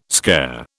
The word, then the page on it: scare: British English